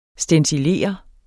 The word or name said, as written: STENCILERE